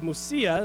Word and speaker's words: Mysia: Then coming to the border of Mysia, and I did look up that word, that is how it's pronounced, they headed north for the province of Bithynia.